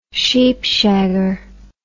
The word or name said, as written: sheepshagger